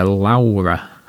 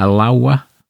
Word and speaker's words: Laowa: It's a Laowa. A Laowa.